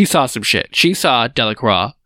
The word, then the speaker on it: Delacroix: It's his name's hard to pronounce. It's Delacroix. – Delacroix. It has an X in it, and it's just so unnecessary. Like, what's up with French? – It's French.